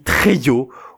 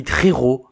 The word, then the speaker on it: Trejo: C'est comme les Américains qui parlent de Danny Trejo au lieu de Danny Trejo, hein.